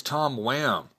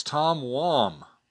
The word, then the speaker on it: Tom Wham: I always grew up thinking it was Tom Wham. Kind of like I always thought Nate Treme. Was that how you pronounce Nate Treme's name? I always said Tom Wham, but it's Tom Wom.